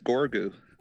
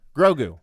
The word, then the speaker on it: Grogu: Who's this Gorgu? Am I saying it wrong again? Grogu.